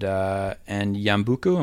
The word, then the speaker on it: Yambuku: So I wonder if you could take us back to 1976 and Yambuku, am I saying that correctly? – I believe so, yes.